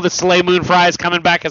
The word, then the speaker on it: Soleil Moon Frye: Soleil Moon Frye.